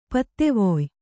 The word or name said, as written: ಪತ್ತೆವೊಯ್